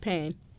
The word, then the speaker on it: Pan: Is it P-A-N or just Pan? – Pan.